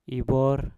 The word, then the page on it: bor: Mpur